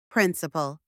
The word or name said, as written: principal